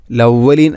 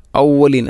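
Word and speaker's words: ഔവലീൻ (الأولين): അസാതീറുൽ അവലീൻ, ലവലീൻ അല്ല ഔവലീൻ, ഹംസയെ കൊണ്ടാണ് പറയേണ്ടത്.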